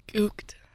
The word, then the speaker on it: gooked: Okay, now there's some questionable pronouncing of this one, but I'm going to go with gooked. – Gooked. – It's with a G. G-O-O-K-E-D.